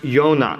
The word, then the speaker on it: Jonah: The name Jonah is pronounced Yonah in Hebrew. It's with a Y, not a J.